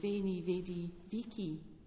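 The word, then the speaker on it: Veni, vidi, vici: When I did Latin in the early 70s, my teacher was saying the V pronunciation, Veni, Vedi, Vici.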